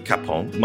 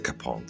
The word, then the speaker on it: Chapon: So is it, it's spelt C-H-A-P-O-N, but that's not how it's pronounced, is it? – Well, I think my in-laws say Chapon. – They do. See, we had Camille from French Today on last week, and her pronunciation is a Chapon, so she doesn't pronounce the H.